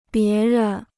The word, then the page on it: 别人: bié ren